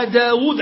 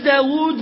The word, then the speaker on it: داود: أن النبي صلى الله عليه وسلم قال بأن دعاء داود عليه الصلاة والسلام، توقفنا عن داود ولا لا؟